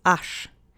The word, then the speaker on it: aż: The next word is aż. I know it says aż, but nobody ever says it that way. It's pronounced with the SZ phoneme sound. Sh.